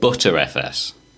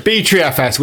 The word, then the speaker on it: BTRFS: It's BTRFS, because butter is no shorter. And just, if you're going to call anything, call it BTRFS, which is what it is.